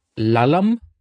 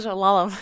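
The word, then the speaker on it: Lulham: Και κέρδισε μαζί με τον Chris Λάλαμ, θα τονίσω έτσι το όνομά του, θα το προφέρω έτσι μάλλον το όνομά του. Γράφεται Λάλχαμ, εικάζω ότι το H είναι silent, το είχαμε, δεν υπάρχει.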